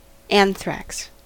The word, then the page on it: anthrax: American English